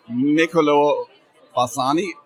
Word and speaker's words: Niccolò Bassani: So now I'm standing next to Niccolò Bassani. Am I pronouncing that correctly? – Yeah, it's perfect.